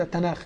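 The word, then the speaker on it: تناخ (תנ״ך): تحت هو كاتب التوراة والانبياء والمكتوبات، ده هو الترجمة يعني، لكن هي انا قلت تنخ، هو كاتب تنخ، هي في العبري ثلاث حروف، هي في العبري ثلاث حروف، لكن بينطوها ايه؟ تنخ، عندهم فتح وعندهم تشكيل وبيقروها كده تناخ.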